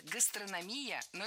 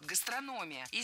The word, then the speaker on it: гастрономия: Много раз мы услышали вариант «гастрономия», но это неправильно. Ударение в этом слове падает на третий слог – «гастрономия». И здесь тоже есть похожая шпаргалка – «гастроном», «гастрономия».